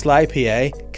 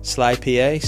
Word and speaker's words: SLYPA: That's SLYPA, kind of like the beer IPA, the Indian Pale Ale, except instead of I, because Sly rhymes with I, see what I've done? SLYPA, so Sly Pale Ale, and 15 for 15% off.